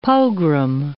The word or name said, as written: pogrom